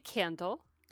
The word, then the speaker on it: Kandle: It's actually Kandle.